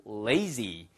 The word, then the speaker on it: lazy: It's not that I'm lazy. 그래서 L-A-Z-Y 그러면 lazy. Lazy 아시겠죠? 자, 됐고요. Z 발음만 하나 살려보세요. lazy 아니고요. 위치아와 아래치아가 살짝 갈리면서 혀끝을 딱 붙여보세요. Z, Z, Z 아시겠죠? 그래서 lazy.